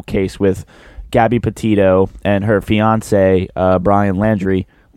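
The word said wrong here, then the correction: Laundrie: No, Laundry. Sorry.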